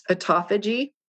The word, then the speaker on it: autophagy: It's spelled auto, like the car, autophagy, P-H-A-G-Y. It's pronounced autophagy.